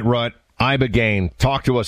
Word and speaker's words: Ibogaine: Ibogaine. Remember the name. Is that pronounced relatively properly, would you say? – Yes, sir.